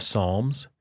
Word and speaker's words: Psalms: It's a funny spelling, P-S-A-L-M-S. And the Psalms really are songs. It sounds like the word song. Psalms are songs.